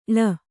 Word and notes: ಳ: ḷa

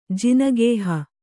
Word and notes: ಜಿನಗೇಹ: Kannada

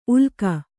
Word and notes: ಉಲ್ಕ: ulka

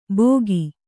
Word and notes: ಭೋಗಿ: kannada

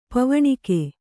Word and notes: ಪೋವಣಿಕೆ: pōvaṇike

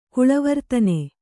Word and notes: ಕುಲವರ್ಧನ: Kannada

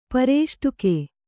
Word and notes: ಪೃಷ್ಠಕ: kannada

